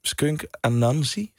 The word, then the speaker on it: Skunk Anansie: Maar eerst Skunk Anansie, zeg ik het goed? – Yes.